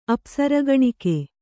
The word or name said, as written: ಅಪ್ಸರಗಣಿಕೆ